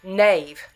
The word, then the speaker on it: Nave: So, and your last name, Nav or Navi? – Nave, like Nave. – Nave. – Okay, I know a couple of people with the same last name, but they always pronounce it Navi.